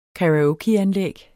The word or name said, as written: karaokeanlæg